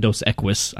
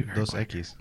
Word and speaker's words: Dos Equis: How do you pronounce this? Because I've heard Dos Equis, Dos Equis. Of course, it's not Dos Equis. I know that. But I've heard like a whole bunch of different pronunciations. – Thank you. – Dos Equis. – Okay, that's what I thought. – Equis, yeah. It's X. Equis is X.